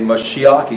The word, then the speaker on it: Mashiach: Anointed, this word right here, is the word, you can see right there, Mashiach. I say Mashiach, you can pronounce it how you want to.